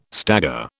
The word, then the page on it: stagger: British English